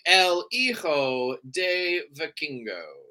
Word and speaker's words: El Hijo de Vikingo: So far, it is definitely Kenny Omega versus Vikingo. Is that how you say it? – El Hijo de Vikingo.